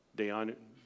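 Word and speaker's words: Dayenu: But in part of the Passover, they have something called the Dayanu. And I'm probably saying it wrong. Am I saying it right, Scott? Dayanu. Okay. Dayanu. Okay.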